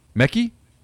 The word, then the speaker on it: Mekhi: Mekhi, I don't know how you pronounce that, Wingo. M-E-K-H-I. Mekhi? – I would say Mekhi.